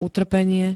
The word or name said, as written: utrpenie